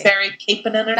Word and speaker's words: Kasperi Kapanen: Kasperi Kapanen.